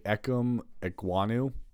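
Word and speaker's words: Ikem Ekwonu: I'm going to say Ekum Ekwonu. Is that how I'm saying that? Am I saying that correctly? – Yep.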